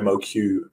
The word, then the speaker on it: MOQ: So reducing your MOQ may help. Am I saying that correctly, Warren? – You absolutely nailed it, yeah.